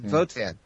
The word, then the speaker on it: Wotan: Wotan is the Germanic, the Anglo-Saxon way of saying Odin. But that's how you would pronounce it. It's Wotan.